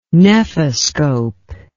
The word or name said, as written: nephoscope